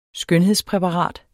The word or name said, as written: skønhedspræparat